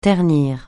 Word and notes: ternir: francais

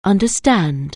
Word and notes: understand: anglais

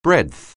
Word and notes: breadth: anglais

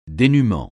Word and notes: dénuement: français

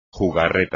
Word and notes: jugarreta: Spanish

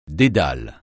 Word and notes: dédale: French

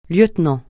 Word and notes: lieutenant: francais

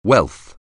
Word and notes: wealth: anglais

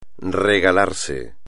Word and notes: regalarse: espagnol

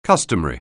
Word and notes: customary: anglais

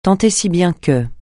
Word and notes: tant et si bien que: francais

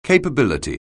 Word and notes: capability: anglais

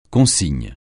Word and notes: consigne: francais

